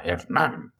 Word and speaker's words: Hernán: Hernán me gusta porque nombre de alguien fuerte, con espada, no sé, tiene H, ¿no? Y un acento en la A, Hernán.